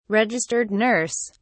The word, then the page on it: registered nurse: British English